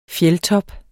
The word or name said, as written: fjeldtop